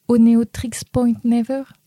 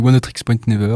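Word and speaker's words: Oneohtrix Point Never: Oneotrix Point Never. – Ouais, c'est une bonne prononciation. Moi, je le dis Oneotrix Point Never, ou on peut l'appeler OPN pour les familiers.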